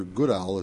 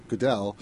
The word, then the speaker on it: Goodell: It's just that on a larger scale, it's still, why did Roger Goodell, is that how you pronounce his name? – Yeah. – Why did the NFL commissioner... – Goodell.